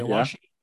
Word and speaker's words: Aoashi: Is Aoashi, I think that's how you pronounce the name. – Okay. Aoashi. Yeah. – What's that one about? A-S-H-I.